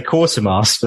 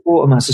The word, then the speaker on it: Quatermass: Because I would say Quatermass, but that doesn't sound right. It's Quatermass, especially the Americans. It's Quatermass 7. I think because Malcolm's studio is called the Quatermass Sound Lab after the Quatermass books or films or TV show, whatever, you know, Quatermass in the Pit and those movies.